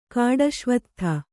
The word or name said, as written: ಕಾಡುಅಶ್ವತ್ಥ